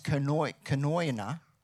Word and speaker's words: koinonia: The word fellowship in the Greek is pronounced koinonia, and it literally means two things.